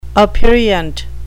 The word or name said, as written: aperient